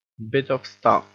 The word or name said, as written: bit of stuff